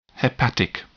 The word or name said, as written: hepatic